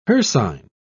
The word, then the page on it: hircine: British English